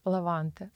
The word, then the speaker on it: лаванте: Лаванти? Лаванте? – Лаванте.